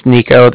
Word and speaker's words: Niko: Again, my name's Nico. That's spelled N-I-K-O.